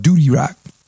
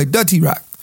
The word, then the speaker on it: Dutty Rock: I used to call it Duty Rock. But anyway, Dutty Rock.